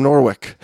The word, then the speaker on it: Norwich: He says, hey, I'm the guy from Norwich. It's pronounced Norwich, like in sandwich.